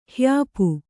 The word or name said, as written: ಹ್ಯಾಪು